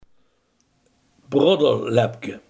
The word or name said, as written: proeflapje